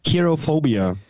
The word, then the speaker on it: Kirophobia: mir helfen, wie spricht man es aus? – Kirophobia. Genau. Kirophobia.